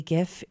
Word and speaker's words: GIF: Yes, GIF. I say GIF. It is graphic. The G stands for graphic, so that's a hard G.